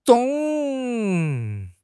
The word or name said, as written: 幢